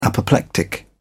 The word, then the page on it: apoplectic: British English